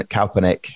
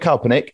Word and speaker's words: Kaepernick: Uh, I would put number two uh, in that power ranking is uh, Colin Kaepernick, uh, and that's how I'm pronouncing it. I've watched a few documentaries, mm-hmm. – and uh, there's quite a few, you know, how you pronounce his surname. But I'm going with Kaepernick.